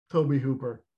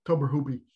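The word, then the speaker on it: Tobe Hooper: And I gotta give it to Toby Hooper, or as I mispronounced him when I was on the Everyone Is Hot podcast, Tober Hoopy.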